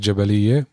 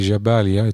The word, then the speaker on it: Jabalia: Here in Jaffa, we call it Jabaliyeh, but it's actually Jabalia. It's written in Arabic as Jabalia.